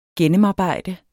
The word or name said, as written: gennemarbejde